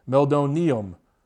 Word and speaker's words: meldonium: I've also heard it pronounced Meldonium. I've heard it pronounced a bunch of different ways. I say Meldonium.